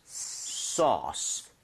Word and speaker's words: sauce: AU나 AW 스펠링이 나오면 O보다 조금만 입모양을 벌려준다. 이 느낌. 그래서 소스보다는요. 소스. sauce 살짝만 벌리시면 되거든요.